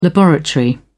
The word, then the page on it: laboratory: British English